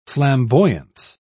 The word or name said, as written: flamboyance